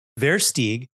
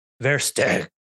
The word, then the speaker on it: Versteeg: Today's episode, we had Alan, well, the Americanized pronunciation would be Versteeg, but as a South African, I trained for this a little bit. It's pronounced Versteeg.